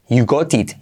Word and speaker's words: you got it: You got it, you got it. Hay que pronunciarlo así, ¿vale? You got it. T como R suave. Y la última T casi no se dice.